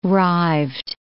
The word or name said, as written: rived